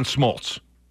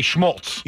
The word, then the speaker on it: Smoltz: Like, for example, John Smoltz. – Yeah. – Okay. And you say Schmoltz. – You say Schmoltz all the time.